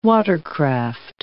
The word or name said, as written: watercraft